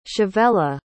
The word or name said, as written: chavela